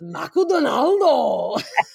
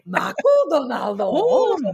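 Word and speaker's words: Macudonaldo (マクドナルド): And so I was so excited then to always pronounce Macudonaldo. We still do it. So that's another one I've kept. Macudonaldo.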